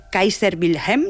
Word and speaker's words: Kaiser Wilhelm: que está sobre la iglesia Kaiser Wilhelm, ¿lo he dicho bien? – Lo has dicho muy bien, es una iglesia que está...